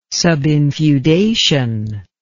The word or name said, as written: subinfeudation